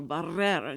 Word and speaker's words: Barrera: She would not let anybody say her name without rolling the R. Her name was Elmo Barrera, and she would roll those R's.